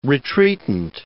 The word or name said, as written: retreatant